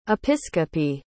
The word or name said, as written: episcope